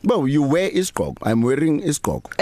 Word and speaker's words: isigqoko: So I'm going to spell it two different ways, and you know why I need to spell. So Isigqoko. – Yes. – And then it's also Isigqoko without the second I. – Is that how we pronounce it? – How we pronounce it, yes. So you would have an apostrophe where you'd have the other I. – Yes. – So isigqoko, but when people speak, they say isigqoko.